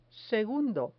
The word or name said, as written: segundo